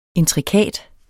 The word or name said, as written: intrikat